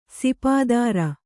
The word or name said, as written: ಸುಪುತ್ರಿ